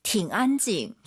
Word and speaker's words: 挺安静: 挺安静. – 저 선생님 발음 어때요? 팅 안 올라가죠? 뒤에가 일성이니까 이 삼성이 반삼성이에요. 挺安静.